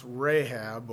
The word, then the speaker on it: Rahab: It's R-H-A-A-B. That's Rahab or Rob. It's the R is not pronounced very, very much, and it's more the H sound, Hob or something like that. I don't know how to speak Hebrew, but it is definitely Rahab is what the word here is.